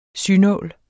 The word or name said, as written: synål